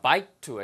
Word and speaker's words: bike to: 자, 발음 하나는 bike to 해서 bike to라고 안 들리고 bike to, bike to처럼 의소리를 집어넣지 않는 것도 한번 확인해 보시고요.